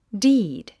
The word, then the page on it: deed: díːd; ディード